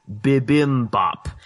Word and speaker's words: Bibimbap: There's an episode of MasterChef that just aired this past week surrounding a Korean dish, the transliteration and pronunciation of which is Bibimbap.